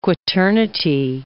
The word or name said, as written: quaternity